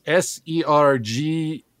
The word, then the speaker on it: Sergei: By the way, my name is spelled Sergei, and you spelled it Sir space capital Gay. So it's phonetically correct, but is not the way I spell it, okay? – That was a joke.